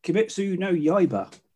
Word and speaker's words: Kimetsu no Yaiba: But there is a TV series, and its full title is known as Demon Slayer Kimetsu no Yaiba. Probably pronounced it wrong, but... – I think you actually pronounced it correctly.